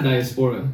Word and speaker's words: diaspora: Diaspora. – Oh yeah, I think I'm scared to now mispronounce it. – Diaspora. – It's not diaspora. Diaspora.